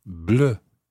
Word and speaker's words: bleu: N-O-I-R-E. But un livre noir. N-O-I-R. Exactly the same happens with bleu. That's spelled B-L-E-U.